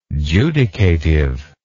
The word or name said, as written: judicative